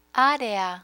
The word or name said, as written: area